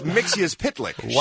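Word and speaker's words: Mxyzptlk: It's Mixius Pitlick.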